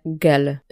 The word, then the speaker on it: GEL: Walutą Gruzji jest lari, natomiast w skrócie pisze się gel, jak żel.